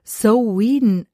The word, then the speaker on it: Samhain: Weil es geschrieben ist es ja Samhain, oder? – Ja, genau. Eigentlich wird es Samhain ausgesprochen. Ist ein altes keltisches Wort und bedeutet Ende des Sommers.